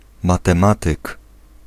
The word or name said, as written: mathematician